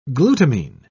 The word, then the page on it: glutamine: British English